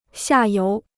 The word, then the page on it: 下游: xià yóu